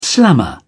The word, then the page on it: slammer: British English